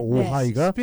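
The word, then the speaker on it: Ojai: 아, 많은 분들이 오하이 그러니까 오하이 생각하시는데 이게 J를 쓰더라고요. 그, 그렇죠? 히스패닉에서 OJAI입니다. 네. 오하이가 스페니지 네, 발음이 그래요. – 네. 오자이가 하이 할 때 H가 아니고 JAY입니다. 네.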